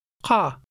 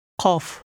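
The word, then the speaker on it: Qa (قاف): Number 11 begins with a letter whose sound is difficult for speakers of English to pronounce. Here it's connected to an Alif. – Repeat number 11 after the speaker. – Qa. Qa.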